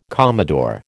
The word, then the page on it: commodore: American English